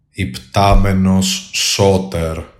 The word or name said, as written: IPTAMENOS SOTER